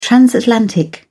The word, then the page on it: transatlantic: British English